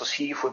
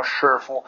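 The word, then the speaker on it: shifu (师傅): Adesso sifu in mandarino shifu è una medesima pronuncia per due differenti concetti.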